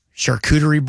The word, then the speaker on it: charcuterie: I had one of those charcuterie boards. Am I saying that right? – Yeah.